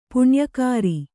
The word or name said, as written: ಪುಣ್ಯಕಾರಿ